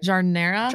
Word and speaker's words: jardinera: Am I saying it right? – Jardinera? – Jardinera. – Jardinera, yes. – Like the air that we breathe, jardinera.